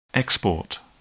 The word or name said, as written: export